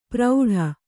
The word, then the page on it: ಪ್ರೌಢ: Kannada